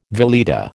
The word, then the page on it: veleta: American English